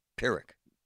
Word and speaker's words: Pyrrhic: Pyrrhic. You said it right.